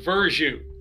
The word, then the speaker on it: Verzuh: My last name is pronounced Verzuh.